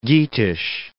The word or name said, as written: Geatish